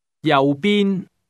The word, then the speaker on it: 右边: That's pronounced with two low level tones. – 再讲一次。 – 右边。